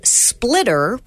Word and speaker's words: Splittr: They're called Splitter, which is spelled SPLITTR, and Splitwise.